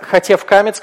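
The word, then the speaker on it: хатеф камец: Это у нас всегда камец хатуф, который произносится как О, и вместе получается хатев камец, который произносится как сверхкраткий О.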